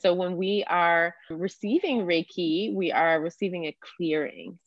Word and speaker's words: Reiki: Yeah, so Reiki, and you can pronounce it a bunch of different ways, but sometimes I pair it together as the two parts of the word. So Rei, meaning universal, and Ki, meaning energy.